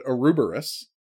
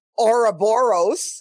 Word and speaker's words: Ouroboros: So let's move on to the next episode, Ouroboros. – Or Ouroboros. – Ouroboros. Depending know. – On how you want to pronounce it. – Or Ouroboros. – Ouroboros. People like to pronounce it differently, is the message we're getting at here.